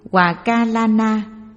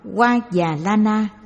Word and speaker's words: Hòa Ca La Na: Hòa Ca La Na, cũng đọc là Hoa Già La Na,